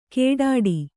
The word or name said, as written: ಕೇಡಾಡಿ